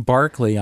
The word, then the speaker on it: Berkeley: In England, that particular construct, that ERK, is uh, often pronounced ARK. And there is also Berkeley Square in London, which is actually would look to us like Berkeley Square.